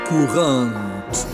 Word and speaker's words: courante: Courante. Courante se escribe courante y a veces se emplea la palabra italiana corrente o se dice, bueno, castellanizando, courant o courant, pero no tiene nada que ver con esto, jóvenes.